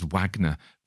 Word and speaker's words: Wagner: He was an American chap, so he possibly pronounced his name rather barbarously as Wagner. But I guess it's his name, so he can pronounce it how he wants, even though he is wrong.